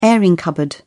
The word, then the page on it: airing cupboard: British English